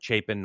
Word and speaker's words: Chapen: Now, it's spelled C-H-A-P-E-N, but I listened to an interview with him, and he pronounces it Chapin, not Chapin.